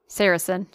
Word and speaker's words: Saracen: Saracen. – Saracen.